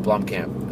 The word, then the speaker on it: Blomkamp: Blomkamp.